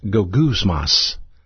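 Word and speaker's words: gogousmas (γογγυσμός): And it's pronounced gogousmas, gogousmas.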